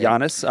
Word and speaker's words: Giannis: I always go hard G on that. Maybe. Giannis. – Giannis, I thought I heard. – As a G. – Sure. – Oh, people on Twitter, like, I did like a 90-minute podcast with Amir this past week, and people were like, after all the 90 minutes, the only thing they said was, why do you pronounce the G in Giannis?